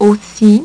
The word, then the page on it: aussi: French